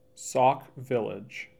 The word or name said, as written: Sauk Village